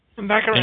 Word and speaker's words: Baccarin: Baccarin. – Baccarin.